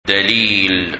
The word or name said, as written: Dalil